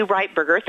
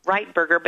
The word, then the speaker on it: Ritberger: It's actually Ritberger. Thank you for pronouncing it right. Ritberger.